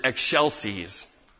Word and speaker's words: excelsis: The Latin word is excelsis. You might think of eggshells if you want to pronounce it the way that we pronounce it as we sing.